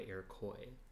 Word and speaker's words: Iroquois: I extensively talked about the Iroquois Theater, and as much as that is exactly how you pronounce that French word, Iroquois, it's based off of Native Americans, and they go by Iroquois.